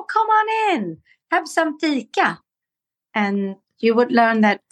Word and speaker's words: fika: It's fika. – Ooh. – And it's spelled F-I-K-A.